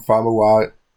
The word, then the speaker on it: Famuyiwa: Famuyiwa.